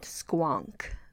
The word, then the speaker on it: Squonk: Squonk. It is spelled How do you spell? – S-Q-U-O-N-K.